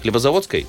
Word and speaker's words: Хлебозаводской: Правильно же говорю? С ударением Хлебозаводской? – Да, наверняка. Все верно. – Нет, Хлебозаводской. Вот меня тут исправляют.